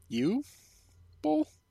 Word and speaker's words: Uwe Boll: I'm pretty sure it's pronounced you Boll.